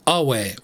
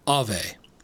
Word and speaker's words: Ave: You may have noted that when I sign off my episodes with Awe Satana, I say Awe rather than Ave. This approach to Latin pronunciation is called restored classical pronunciation and is supposed to approximate the way the language was pronounced when it was originally spoken, which contrasts with ecclesiastical pronunciation, the pronunciation used by the Catholic Church.